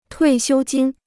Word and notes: 退休金: tuì xiū jīn

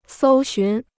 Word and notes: 搜寻: sōu xún

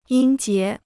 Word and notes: 音节: yīn jié

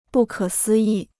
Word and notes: 不可思议: bù kě sī yì

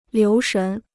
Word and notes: 留神: liú shén

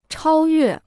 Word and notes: 超越: chāo yuè

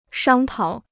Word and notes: 商讨: shāng tǎo

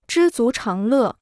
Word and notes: 知足常乐: zhī zú cháng lè

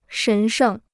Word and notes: 神圣: shén shèng

